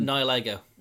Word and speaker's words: Nihilego: But it's like, because all the questions we had, we had the big question with UB01, which is, I can't pronounce it properly. It's like, it's meant to be Nihilism. – Nihilego. – Nihilego. So Nihilism and Lego.